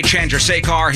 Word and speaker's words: Chandrasekhar: Jay Chandrasekhar, he's going to be here from Broken Lizard. Did I not say it right? – No, you're nailing it.